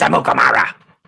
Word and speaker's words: Amukamara: You got to say it like Charlie Casserly when he was drafted. Prince Amukamara.